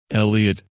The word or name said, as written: Elliot